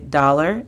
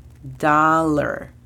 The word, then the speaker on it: dollar: La pronunciación fonética de dollar es D-A-L-R. Dollar. Dollar. Cent significa centavo. La pronunciación fonética de esta es S-E-N-T.